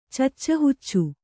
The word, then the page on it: ಚಚ್ಚಹುಚ್ಚು: kannada